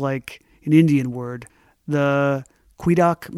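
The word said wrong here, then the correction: Quedagh: merchant? – The Kida merchant is how the people usually pronounce it.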